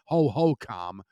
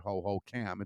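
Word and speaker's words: Hohokam: Ho-Ho-Com, and that is how it's pronounced. All the years you've heard Ho-Ho-Cam, it's Ho-Ho-Com,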